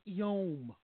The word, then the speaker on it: yom: a Hebrew word that is pronounced yom.